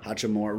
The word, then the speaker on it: Hachimura: Hachimura.